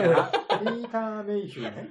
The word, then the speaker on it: ピーター・メイヒュー: 発音おかしいな。 – ピーターメイヒューね。